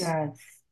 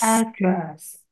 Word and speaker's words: address: address? – 那还有另外一个发音，address. – Address. – Address, address 都可以。啊，重音呢？重音一个第一，一个第二音节了。